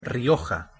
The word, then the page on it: rioja: Spanish